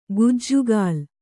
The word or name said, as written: ಕಜ್ಜಗಲಿ